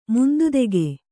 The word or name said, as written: ಮುಂದುದೆಗೆ